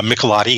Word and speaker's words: Michelotti: So that car was designed by Giovanni Michelotti. Lotti? Am I saying that right? Michelotti? – Michelotti, yes.